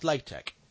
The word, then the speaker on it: LaTeX: Well, yeah, but LaTeX is pronounced LaTeX.